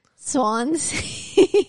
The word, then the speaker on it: Swansea: Swansea.